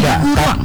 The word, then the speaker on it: baju kurung: Baju bajunya sebenarnya kan baju kurung ya, tapi di Sumatera Barat kita memanggilnya baju kuruang.